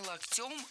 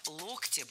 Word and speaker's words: локтем: Вы едете вместе в общественном транспорте, и он там случайно тебя локтем задел. – Задел не локтем, а локтем. Слово «локоть» склоняется по следующей схеме. В единственном числе ударным остается корень слова «локтя», «локтю», «локтя», «локтем».